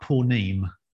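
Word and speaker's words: ponim (פנים): Now, what's interesting is in that little passage from Jonah and from Psalm 139, the word that's translated presence, the Hebrew word is pronounced something like ponim.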